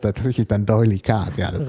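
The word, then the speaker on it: D'Oyly Carte: Ach, Doylicard, nicht. Also ich habe das Französisch ausgesprochen, weil der erste Teil des Namens Französisch. – Richtig. – Ist, aber der ist wahrscheinlich völlig. – Der ist in London geboren und in London gestorben.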